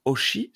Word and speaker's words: Hoshi: J'apprends alors que Oshi s'écrit en réalité Oshi. Parce que moi, j'écrivais O-S-H-I, et en fait, ça s'écrit H-O-S-H-I.